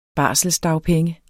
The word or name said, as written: barselsdagpenge